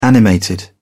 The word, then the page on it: animated: British English